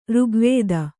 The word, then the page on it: ಋಗ್ವೇದ: řgvēda